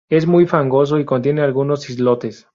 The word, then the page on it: fangoso: spanisch